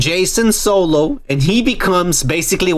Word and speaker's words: Jaycen: Oh, well, his name is going to be Jason. – Jason. No, but it's spelled J-A-Y-C-E-N. – Oh, there you go. – Yeah, yeah. It's spelled different.